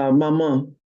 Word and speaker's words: Maman: first time I saw, and you have to pronounce this for me because I mispronounce it in my mind, but what do you call your mother? – Maman. – Mama. Okay. Mm-hmm.